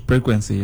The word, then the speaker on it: frequency: Frequency, yeah. – With P or with F? – F. Frequency, F.